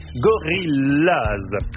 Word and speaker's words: Gorillaz: Vous mettez en doute en allemand, Gorillaz se prononce comme en anglais, Gorillaz.